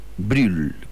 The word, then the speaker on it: Brüll: Se escribe Brüll, pero con diéresis en la U, por lo tanto lo pronunciamos Brüll, compositor de origen germánico, pero nacido en Moravia, que es actualmente una de las dos regiones de la República Checa.